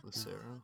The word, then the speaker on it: Lucero: Lucero. – Yeah, Lucero.